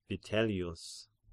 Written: Vitellius

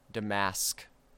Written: Damask

